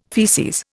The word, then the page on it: faeces: Indian